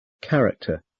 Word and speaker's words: character: In these words, CH is k. Character, chemistry, choir, chorus, stomach, ache, anchor, architect, mechanic, orchestra, psychologist, technical.